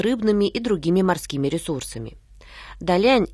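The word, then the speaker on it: Далянь: Та – это означает «большой», произносится в четвертом тоне, а Лен – «соединяться», произносится во втором тоне. Повторите за мной. Далиан.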